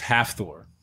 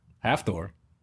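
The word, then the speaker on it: Hafþór: It's Half-Thor. – I'm sorry, it's what? – Half-Thor.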